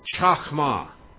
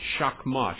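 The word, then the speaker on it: chochmah: In Hebrew, it's chachma. English letters, C-H-O-C-H-M-A-H. C-H-O-C-H-M-A-H. Chachma, chachma. That's how they say it in Hebrew.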